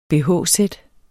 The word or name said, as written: bh-sæt